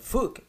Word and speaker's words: phoque: You know, it could be like fuck. It's spelled F, you know, oh, let's go back. – P-H-O something? – Oh, P-H-O. – Fuck. – P H O Q U E.